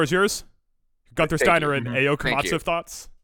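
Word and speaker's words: Ayao Komatsu: He gets replaced by, feel free to correct me on the pronunciation, Ayo Komatsu? – Ayo Komatsu, yeah.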